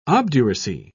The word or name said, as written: obduracy